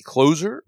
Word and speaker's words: cloze: It's called the, how do you pronounce that? C-L-O-Z-E. – C-L-O-Z-E, closer? What the hell is that? I don't even know what that is. – It's called a close prompt.